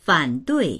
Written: fan3dui4

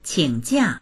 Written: qing3jia4

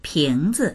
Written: ping2zi

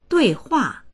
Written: dui4hua4

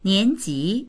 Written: nian2ji2

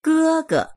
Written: ge1ge

